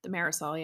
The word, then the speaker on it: thimerosal: Particular concerns over the use of thimerosal, is that how you say it? – The Marisol, yeah.